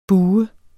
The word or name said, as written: BUHEDE